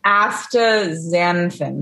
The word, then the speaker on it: astaxanthin: For the listeners, showing us a bottle of some shit I can't pronounce. Aztaxanthin. – I almost said Xanax. – Astaxanthin. – Yeah. – Okay. Astaxanthin.